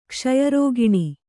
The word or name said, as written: ಕ್ಷಯರೋಗಾಣು